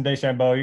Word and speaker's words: DeChambeau: Did I pronounce that correctly? DeChambeau? Is that right? – Yep, Bryson DeChambeau.